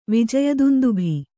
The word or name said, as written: ವಿಜಯದುಂದುಭಿ